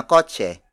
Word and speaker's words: kotse: For example, itim na kotse. Itim na kotse. Itim na kotse. It means black car. Kotse the Tagalog word for car is spelled as K O T S E.